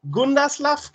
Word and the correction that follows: Grundislav: Games. – Gundaslav. – Grundislav. – Grundislav Games. Okay.